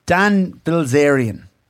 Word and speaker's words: Dan Bilzerian: Dan Bilzerian.